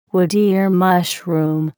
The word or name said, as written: wood ear mushroom